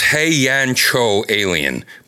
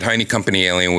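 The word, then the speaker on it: Heiankyo Alien: She said it's pronounced Heian-Cho Alien. But she said Heine Company Alien would be acceptable as well.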